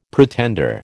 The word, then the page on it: pretender: American English